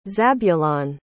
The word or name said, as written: zabulon